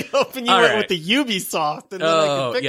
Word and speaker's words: Ubisoft: It's time for us to talk about is it Ubisoft or Ubisoft? I feel like I always say it wrong. – No, what are you going to say? And then I'll tell you whether you're right. – I'm going to say Ubisoft. – Yeah, that's right.